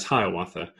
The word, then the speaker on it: Hiawatha: Probably the specific instance was a man named Hiawatha, which is pronounced generally as Hiawatha.